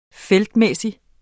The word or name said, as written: feltmæssig